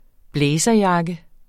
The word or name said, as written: blazerjakke